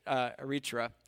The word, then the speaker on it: Eritrea: or some people say Eritrea. I think people from there pronounce it Eritrea, so I'll say that.